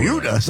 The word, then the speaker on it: Buda: Buda. It's spelled B-U-D-A, and I looked it up. It is pronounced like beauty.